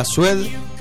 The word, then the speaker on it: Suede: Bueno, ahí pasaba la banda británica Suede. ¿Lo pronuncié bien? Sí, CP asiente, así que está todo bien.